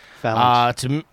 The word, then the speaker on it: Phalanx: in Origins with the Phalanx. I think that's how you say it. – Phalanx. – Okay, there you go.